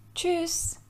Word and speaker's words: Tschüss: Tschüss. Tschüss. Almanca'da hoşça kal anlamına gelen ve en sık kullanılan vedalaşma kalıplarından biridir. Günlük konuşmada genelde ü uzatılarak kullanılır. Yani tschüss şeklinde telaffuz edilir.